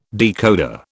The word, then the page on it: decoder: British English